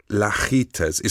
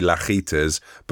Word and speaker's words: Lajitas: didn't ask that lady on the internet how to pronounce it, but it's Lajitas. It's like fajitas, but with an L at the start. So I would say Lajitas, but my dad would say...